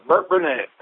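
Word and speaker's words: Burkburnett: Burke Burnett, Texas. Am I saying that right? – Oh, yeah. Burke Burnett.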